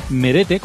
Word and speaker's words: Merete: Es Merete, como suena. Sí. Como suena M-E-R-E. Se pronuncia así.